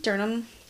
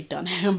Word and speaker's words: Durham: So, they lived in Durham, North Carolina. Though the way that this is spelled, I really want to say Dunham, but it's not. – Durham. – Durham. – Okay. – It's literally like Durham.